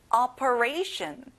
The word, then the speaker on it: operation: 수술, operation. 친구들, ray에 강세를 넣어보세요.